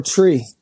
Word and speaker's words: Tree: It's titled Tree, T-R-E-E. Yes, sir. I'm Jamaican, you know what I mean? It's my third studio album, so we pronounce three tree. One, two, tree.